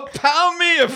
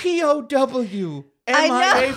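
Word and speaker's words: POW/MIA: That is not how we say that. I'm actually amazed that this made it into the final version of the game. – How do you say it? What's it called? – It's a POW. M-I-A flag.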